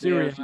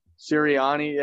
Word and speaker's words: Sirianni: Serini. Sir, maybe it won't matter if I mispronounce his Sirianni. name. – Sirianni, yeah, Italian guy.